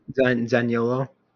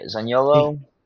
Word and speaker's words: Zaniolo: Zaniolo. – Yeah, Zaniolo.